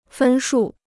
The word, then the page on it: 分数: fēn shù